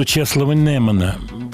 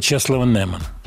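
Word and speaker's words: Czesław Niemen: По поводу Чеслова Немана, я вот так произношу его имя, так, как было принято.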